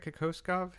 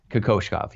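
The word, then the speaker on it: Kokoškov: Let me ask you about that, because Kokoshkov, did I say it right, Kokoshkov? – You did. Kokoshkov, yes.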